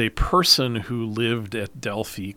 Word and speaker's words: Delphi: And it's a place that you and I might pronounce Delphi. The Greeks pronounce it Delphi.